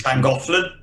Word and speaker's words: Llangollen: He was doing the various events at Longleat and the Doctor Who experience at that Welsh place I can never pronounce, Llangollen. – Llangollen. – That's near enough.